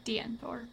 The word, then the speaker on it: Deantoir: I don't think it's Deantor. Is that how I pronounce that? – Yeah, Deantor.